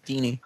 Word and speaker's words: Dini: No, I don't know actually how to pronounce his name. I've seen it written many, many times. – Dini.